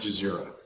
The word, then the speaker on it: Cizere: Kamishlo is the capital of Jazeera Canton, and Jazeera is spelled C-I-Z-E-R-E, but pronounced Jazeera.